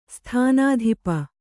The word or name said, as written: ಸ್ಥಾನಾಧಿಪ